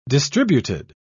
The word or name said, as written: distributed